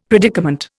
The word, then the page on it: predicament: Indian